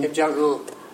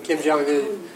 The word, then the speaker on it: Kim Jong-un: Kim Jong-un. Ud. – Ud? – Kim Jong-un.